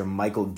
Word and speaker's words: Mychal: Uh, for a proven defensive player that can also swing the bat a little bit, and they also, um, I, I apologize if I butcher this name, um, they also acquired relief pitcher Mychal Givens. I'm pretty sure I said that right, just awkward spelling, um, from the Baltimore Orioles.